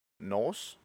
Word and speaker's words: Norce: Det udtales på samme måde som Norse med S, som betyder nordisk.